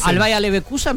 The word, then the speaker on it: Bayer Leverkusen: Al Bayer Leverkusen, me encanta. – Lo dijiste muy bien. – Al Bayern Múnich, vos preferís ver a todos esos. – Al Bayern München y el Bayer Leverkusen.